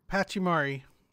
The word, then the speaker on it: Pachimari: The Paramachi hat. Paramachi? I'm saying it wrong. – Pachimari. – Paramachi? – Pachimari. – I typed it wrong.